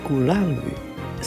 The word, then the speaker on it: Gulangyu: Today, I'm going to talk about Gulangyu.